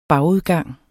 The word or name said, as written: bagudgang